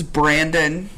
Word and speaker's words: Brendon: Like, people think I say Brenda when I say my name, so. – That's awesome. – And I'm like, hello, this is Brandon.